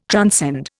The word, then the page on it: transcend: Indian